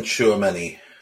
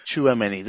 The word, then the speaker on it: Tchouaméni: Tchouaméni, is that right? – Aurelien Tchouaméni. – Tchouaméni, there you go.